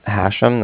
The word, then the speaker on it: Hashem: It's Hashem. That's H-A-S-H-E-M, as in mother at the end.